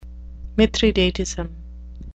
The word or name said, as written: mithridatism